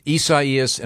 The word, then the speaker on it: Isaias: Said it's it's Isaias, and it's got three different. There's three different pronouncers here.